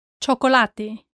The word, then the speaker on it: chocolate: Chocolate, chocolate.